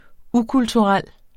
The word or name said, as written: ukulturel